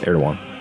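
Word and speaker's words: Erdoğan: It's addressed to Erdogan. Is that how you pronounce it? – Yes, Erdogan.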